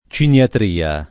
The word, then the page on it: ciniatria: lingua italiana